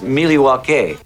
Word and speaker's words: Milwaukee: Actually, it's pronounced Milwaukee.